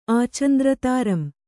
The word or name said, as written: ಆಚಂದ್ರತಾರಂ